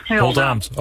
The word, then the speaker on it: Oldambt: En hoe zit dat met Oldambt? Oldambt. – Oldambt. – Ook laatste lettergreep.